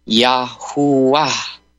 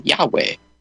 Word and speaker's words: Yahuwah: So Yad, He, Wa, He would be Yahuwah, Yahuwah, the Father. I was mispronouncing it, which I believe. I don't get mad at people for doing it. I did it, you know, Yahweh.